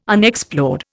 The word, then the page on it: Unexplored: Indian